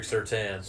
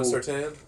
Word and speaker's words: Surtain: Is it Surtain or Surtain? – I've heard Surtain is what I've heard. I'm not positive. They could be butchering it too. – I am Surtain. It's Patrick Surtain. – So we're all going Surtain? – Yeah.